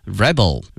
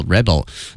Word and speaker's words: rebel: 那么这个词呢它还有的动词的一个意思，表示叛乱啊 rebel，它的发音呢发生了变化，重音往后挪了一下名词叛军叫 rebel。那么这个词啊，大家看新闻的时候啊，特别说一些动荡国家往往出现叛军，会用这个词哈 rebel。希望同学们在听英语新闻的时候呢，注意一下这个词的发音哈。名词是呢 rebel，动词呢是 rebel 哈，起义反抗的意思。